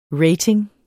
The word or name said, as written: rating